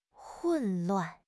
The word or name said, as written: 混乱